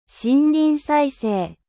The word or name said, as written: 森林再生